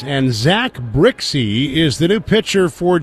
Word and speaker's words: Brzykcy: Zach Brixey's last name is spelled B-R-Z-Y-K-C-Y, pronounced Brixey.